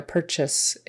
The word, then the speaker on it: purchase: Instead of saying purchase or purchase or whatever you might want to call that, it's more like a purchase is, purchase or purchase, either one, it or a. It's kind of a throwaway vowel because it's an unstressed syllable, right? We're stressing the per, purchase, purchase.